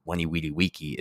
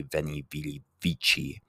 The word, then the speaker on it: veni, vidi, vici: And because weni, weedy, weeky is actually veni, vidi, vici. All right. So if you didn't know, that's how the pronunciation changes dependent on whether you're in classical Latin versus more modernized Latin / Italian.